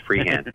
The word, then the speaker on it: Freehan: It's funny, he's saying names like Sheehan, but I know it's pronounced Freehan.